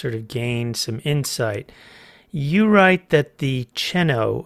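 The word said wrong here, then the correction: Chennault: affair, is that how it's pronounced? It's Chenault. – Chenault, okay. Was perhaps one of the few truly treasonous actions that has occurred that can be attributed to Nixon.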